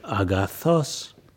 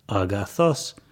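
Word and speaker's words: agathos (ἀγαθός): So if you say the word good in ancient Greek, it's agathos, but actually the accent is on the last element of that word, so your voice would go agathos. You hear my voice rising in pitch at the last syllable, agathos.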